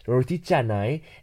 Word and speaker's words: canai: It's spelled C, but in Malaysian, because Malay is it's completely phonetic, so C is pronounced J instead of cake, so it's actually roti canai, and it's very delicate bread.